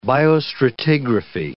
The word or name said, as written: biostratigraphy